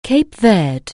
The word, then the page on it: Cape Verde: anglais